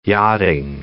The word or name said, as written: yachting